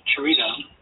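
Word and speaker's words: Cherita: Charita, pronounced just like a chair, Rita, is a short verse that tells a story.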